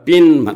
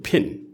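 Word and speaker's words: pin: पिन त नेपाली उच्चारण हो अङ्ग्रेजी शुद्ध भन्नु भने फिन यस्तो हुन्छ त त्यसैले गर्दाखेरि यो अङ्ग्रेजी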